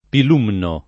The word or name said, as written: Pilumno